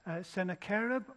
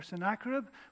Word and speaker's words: Sennacherib: You can pronounce this man's name in two different ways, Sennacherib or Sennacherib, whichever way you pronounce it.